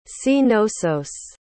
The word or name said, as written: cnossos